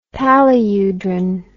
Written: paludrine

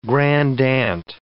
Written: grandaunt

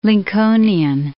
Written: Lincolnian

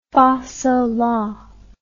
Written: fasola

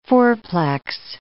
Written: fourplex

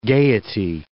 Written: gaiety

